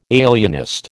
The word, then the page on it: alienist: American English